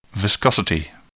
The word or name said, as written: viscosity